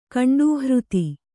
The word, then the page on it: ಕಂಡೂಹೃತಿ: kannada